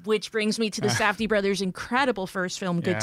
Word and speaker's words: Safdie: I feel like, especially like the directors of Uncut Gems, the Safdie brothers, should I say that right? – Yes.